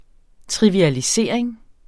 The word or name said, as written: trivialisering